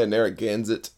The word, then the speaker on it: Narragansett: If they don't have Natty Bo, they have Narragansett. Or how do you pronounce it again? Did I get it right? – Yeah, Narragansett, I guess.